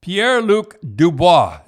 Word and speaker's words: Pierre-Luc Dubois: So anyhow, the trade we should talk about is Pierre-Luc Dubois.